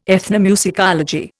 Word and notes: ethnomusicology: Indian

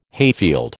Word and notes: hayfield: American English

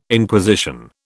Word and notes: Inquisition: British English

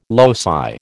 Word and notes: loci: American English

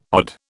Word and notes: odd: British English

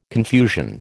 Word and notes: confusion: American English

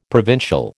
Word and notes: provincial: American English

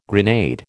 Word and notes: grenade: American English

